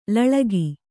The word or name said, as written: ಲಳಗಿ